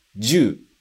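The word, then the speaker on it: ju (銃): E a segunda palavra, agora sim, com a contração e a pronúncia fica ju. Mais uma vez, ju. E o significado é uma pistola, uma arma, ok?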